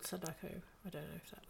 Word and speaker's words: Sadako: And then anyone who's confused, Samara is the American name for Sudoku. The numbers game. She comes out the TV. How do I say it? Sadako.